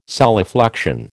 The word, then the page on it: solifluction: American English